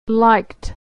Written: liked

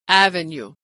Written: avenue

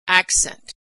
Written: accent

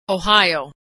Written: Ohio

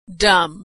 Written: dumb

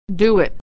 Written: do it

